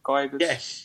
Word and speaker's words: Gyökeres: Gyökeres or something. Gyökeres.